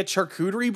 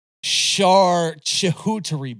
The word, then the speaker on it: charcuterie: Is it like just like a charcuterie board or something? – I believe it is, but you also mispronounced it. It's a charcuterie board.